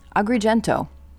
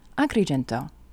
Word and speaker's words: Agrigento: Agrigento, in American, but let's use the proper pronunciation, Agrigento.